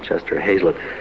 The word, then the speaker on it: Chester Hazlitt: Rank told a long story about Chester Hazlitt, and surprisingly that's the way his name is pronounced, Chester Hazlitt, the great clarinetist with Whiteman, how they'd been playing an engagement in New Orleans.